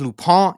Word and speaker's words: Lupin: It looks like Lupin, but it is French. It is pronounced Lupin.